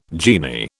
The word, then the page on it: genie: British English